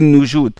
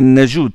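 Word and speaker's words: Najud: Et on dit Asim ibn Abi Najoud parce que certains ils ne prononcent pas bien Najoud, ils disent Najoud ou Najoud. Non, c'est Najoud, c'est comme ça que ça.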